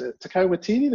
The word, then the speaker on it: Taika Waititi: And I actually heard I always mispronounce his name, it's Taika Waititi, the guy who directed Jojo Rabbit.